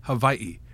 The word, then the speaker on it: Hawaii: Is it Hawaii or Hawaii?" And they say, "It's Hawaii."